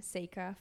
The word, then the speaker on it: Seyka: So it's a play on my name, Seyka, For Goodness Sake. – And it's spelled S-E-Y-K-A.